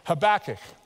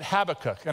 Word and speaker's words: Habakkuk: You can also pronounce it Habakkuk, and actually both pronunciations are correct, believe it or not. But I'm going to go with Habakkuk because it just flows a little better.